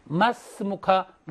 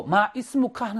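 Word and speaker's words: 마스무카 (ما اسمك): 이스미인데, 아까 여기 보면 이스미 할 때는 여기 모음이 있죠. 이로 발음합니다. 근데 이것은 빌려온 글자라 그랬어요. 그래서 앞에 다른 단어가 오면 이 발음은 되지 않아요. 정관사의 알 중에서 알리프가 발음되지 않듯이. 그래서 마스무카가 됩니다. 마스무카. 물론 아랍 사람도 마이스무카 하는 사람이 있어요.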